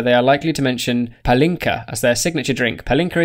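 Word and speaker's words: Pálinka: So it's like the emphasis is on the A. Palinka.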